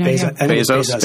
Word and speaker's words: Bezos: And do you think I don't know when Jeff Bezos how do you pronounce that? – I think it's Bezos.